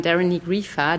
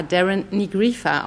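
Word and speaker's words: Doireann Ní Ghríofa: Sonst wüsste ich auch nicht, dass man Darren Nigrifa, Darren Nigrifa ausspricht, äh, weil diese gälische Sprache und die Schreibweise von Namen ist wirklich also abenteuerlich und, und zauberhaft.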